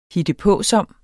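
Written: hittepåsom

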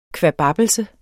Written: kvababbelse

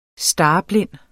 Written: starblind

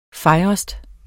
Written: fejrest

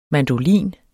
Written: mandolin

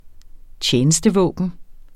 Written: tjenestevåben

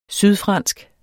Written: sydfransk

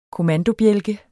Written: kommandobjælke

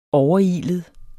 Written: overilet

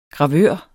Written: gravør

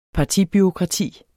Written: partibureaukrati

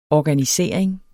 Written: organisering

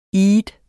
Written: eid

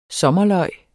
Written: sommerløg

